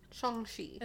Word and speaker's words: Shang-Chi: I think it's a soft CH sound. Shang-Chi. – Instead of Chai.